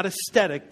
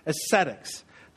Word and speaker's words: ascetic: It's not aesthetic. Take the T out. Ascetics.